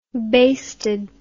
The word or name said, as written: basted